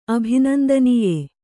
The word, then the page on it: ಅಭಿನಂದನೀಯೆ: abhinandanīye